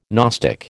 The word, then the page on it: gnostic: American English